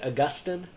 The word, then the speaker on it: Augustine: And the big character in this story is Augustine, Augustine of Hippo. Sometimes you'll hear his name said Augustine. Either pronunciation is okay. One, I believe, comes from a Greek form of his name, and one comes from a Latin form of his name.